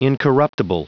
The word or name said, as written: incorruptible